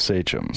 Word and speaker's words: Sachem: It's pronounced Sachem.